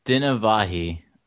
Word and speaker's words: Dinavahi: It's Dinavahi.